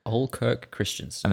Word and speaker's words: Ole Kirk Christiansen: Ole Kirk Christiansen. – Ole Kirk Christiansen. – And I don't think it's like old Dave from out the back. It's like Ole is his name, O-L-E.